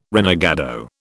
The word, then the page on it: renegado: British English